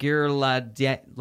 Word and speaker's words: Ghirlandaio: Uh, I think the last name is pronounced Ghirlandaio. Is that how you say it? You nailed it.